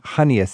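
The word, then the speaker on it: Chania: I think, is how you pronounce it, Kania? Is that where I should be looking at Hania? You mean Hania? – Yeah. – Hania.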